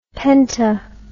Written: penta

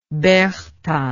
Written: Berchta

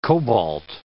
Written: cobalt

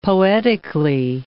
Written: poetically

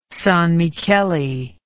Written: Sanmicheli

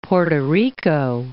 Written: Porto Rico

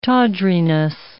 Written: tawdriness

